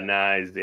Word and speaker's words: Knies: Someone in chat says about plays on FanDuel play Matthews and Knies or however you pronounce his name. – Yeah. Knies. Yeah. Yeah.